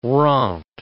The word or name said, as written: rente